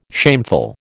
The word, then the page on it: shameful: American English